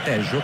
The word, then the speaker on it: Tello: l'argentino Facundo Tello, anzi Tejo, considerato che in Sud America la doppia L non si legge Ye ma si legge Sh,